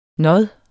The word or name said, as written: nåd